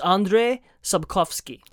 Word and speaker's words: Andrzej Sapkowski: Are we sure that's how that's pronounced? Andrzej? – I spent like 20 minutes looking it up. It's Andrzej Sapkowski.